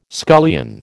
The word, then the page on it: scullion: American English